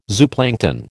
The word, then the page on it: zooplankton: American English